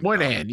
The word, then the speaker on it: Moynihan: Moynihan.